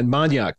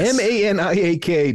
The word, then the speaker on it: maniak: All right, so now you know how to follow Seb and that uh, maniak. That's spelled uh, like maniac, but with a K. – Exactly. – Yes. M A N I A K dot I O maniak dot I O and uh, and follow Seb and uh, on all the socials.